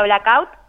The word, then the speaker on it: blackout: haciendo blackout, así lo pronunciamos nosotras.